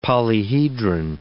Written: polyhedron

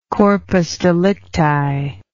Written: corpus delicti

